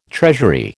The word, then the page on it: treasury: American English